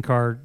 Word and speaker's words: Kaehr: It's at Dustin Carr. My last name is spelled K-A-E-H-R.